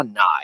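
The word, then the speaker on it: Nighy: And I looked endlessly on how do you pronounce the guy's name. And I saw Nye over and over. So I'm sticking with it.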